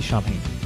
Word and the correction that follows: Samhain: Happy Samhain. – There you go. Yeah. – At least you corrected the pronunciation.